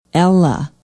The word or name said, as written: ella